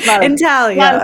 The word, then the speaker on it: intaglio: The G is gone. – I guess it's an Italian word, right? Intaglio.